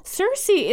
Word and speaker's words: Cersei: Cersei is pronounced like C-I-R-C-E Cersei, who was a nymph, enchantress, and sorceress in Greek mythology.